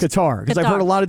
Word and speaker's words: Qatar: It's like guitar. Qatar. There's like an accent on it. Qatar. And you're supposed to roll the R at the end.